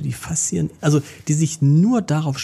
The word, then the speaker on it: Faszien: ich weiß gar nicht, ob ich es richtig ausspreche, die Faszien. – Ja, die Faszien.